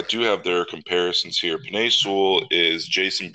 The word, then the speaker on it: Penei Sewell: Penei Sewell, I believe.